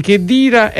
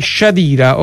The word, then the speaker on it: Khedira: Allora, ci hanno detto che la pronuncia di Khedira è Shadira. Ora ci vogliamo attenere o vogliamo continuare a dire Khedira? No, ci dobbiamo attenere a quello che ha detto Walid Khedira. – Infatti, perché si pronuncia Khedira.